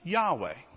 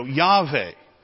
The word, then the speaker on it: Yahweh: And it's pronounced this way, Yahweh. In modern Hebrew, they take that W and they have a V sound. So Yahweh is how it's also pronounced.